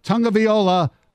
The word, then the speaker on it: Tagovailoa: And the Chargers select quarterback Tua Tagovailoa from Alabama.